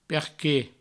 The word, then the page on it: perque: Provençal